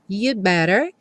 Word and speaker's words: you'd better: Como nós já vimos na seção das estruturas, esse you'd better, o B, vai sumir mesmo. Então vai ficar you'd better.